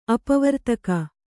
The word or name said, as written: ಅಪವರ್ತಕ